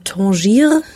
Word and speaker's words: Tanger: Tanger, wie spricht man es eigentlich? Tanger. – Also ich glaube auf Deutsch einfach Tanger. Und ansonsten sagt man Tangier.